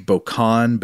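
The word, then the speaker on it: bocannes: Um, I think this word is French, so I think it would be pronounced bocan, but it's B O C A N N E S.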